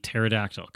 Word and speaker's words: pterodactyl: The pterodactyl, because the P is silent.